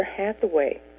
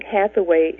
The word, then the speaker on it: Hathaway: Hello, my name is Barbara Hathaway. Some think Hathaway is a funny-sounding name and sometimes pronounce it Hackaway or Hadaway, even Halfway.